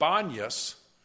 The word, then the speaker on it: Banias: And Banias, it's called that because in the Greek period, they worshipped Pan. You know, might notice I'm pronouncing it Banias because Arabs can't pronounce the P.